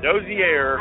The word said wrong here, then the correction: Hozier: I think. I think it's Hozier. – Hozier.